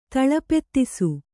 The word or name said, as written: ತಳಪೆತ್ತಿಸು